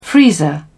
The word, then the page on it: freezer: British English